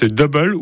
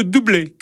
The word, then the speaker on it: Double: The Captain of the Heart, c'était le groupe suisse Double, ou plutôt Doublé, car ça se prononce comme ça apparemment.